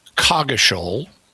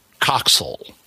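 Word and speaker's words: Coggeshall: And according to what I was able to find, there are two modern English pronunciations in England for this name. One of them is it's pronounced Cogishal. – Okay. – The other is it's pronounced Coxal.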